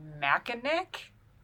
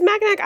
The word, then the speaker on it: Mackinac: But yeah, it's a Michigan, like, straight to Mackinac, Mackinac Island. It's spelled this way, but it's a weird pronunciation.